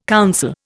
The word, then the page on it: council: Indian